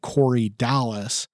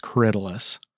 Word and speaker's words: Corydalis: Corydalis is spelled Corydalis, and so it looks like Corydalis, but it's pronounced Corydalis.